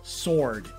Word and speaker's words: sword: Sword. – Sword. Why do I say sword? – Because it's spelled with a W. Maybe you feel I feel like I should pronounce the W.